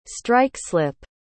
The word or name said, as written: strike-slip